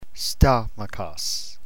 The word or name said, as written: stomachos